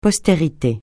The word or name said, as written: postérité